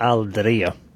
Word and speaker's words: Al Diraya: Hur uttalar man lagets namn? – Det heter Al Diraya. – Ser du, där fick ni det rätta uttalet.